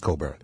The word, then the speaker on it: Cockburn: And that's spelled CoCkBurn, but it's pronounced Coburn.